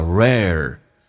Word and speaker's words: rare: OK, 来看一下 saving money is 跟 a 叫 is a rare, 这个单词很难发，先发 a，再发 r，轻轻的卷一下，叫 rare skill。